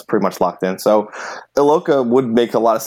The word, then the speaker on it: Iloka: George Iakoa? Did I say that right? The Bengals safety just got. – I think it's Iloka. I think it's just regular. It's pronounced like it's spelled Iloka.